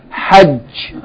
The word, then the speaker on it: Hajj: Yani, Ha, Jim, with Shadda. Hajj.